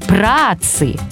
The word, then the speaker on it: праотцы: Обратите внимание на ударение. Правильно, праотец, праотцы.